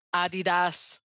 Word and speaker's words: Adidas: It's Coke, it's Nike, it's Adidas, which apparently is how you say that.